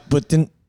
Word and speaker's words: poutine: Is it poutine or poutine? Poutine? – Depends who you're asking, really. – Yeah, what's the official? How do they pronounce it? – Quebecois Putin.